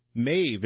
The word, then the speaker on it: Maeve: Mave is one of many new apartment offerings in downtown Raleigh. And Mave is spelled M-A-E-V-E.